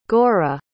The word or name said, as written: gaura